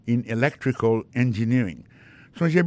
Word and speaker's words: electrical: Également vient dans electrical engineering, accent tombé sur deuxième syllabe ça.